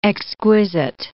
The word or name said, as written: exquisite